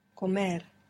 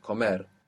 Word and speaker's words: comer: Now, if the word ends in any other consonant that's not N or S, then the natural accent place is to go to the end of the word. And we see this perfectly with any to verb. No? How do you say to eat? – Comer. – Accent on the end. Comer.